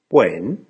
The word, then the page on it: when?: American English